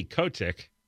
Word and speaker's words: Kotick: The current CEO, Bobby Kotick, that's how you say it, donated to Viragosa in 2018 and did not donate to Newsom even in last year's recall.